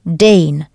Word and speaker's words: den: That's D-E-N, pronounced Dane, when it's the victim of the verb.